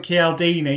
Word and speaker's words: Cialdini: Sorry, I pronounce his name different. Robert Cialdini.